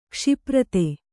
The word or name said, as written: ಕ್ಷಿಪ್ರತೆ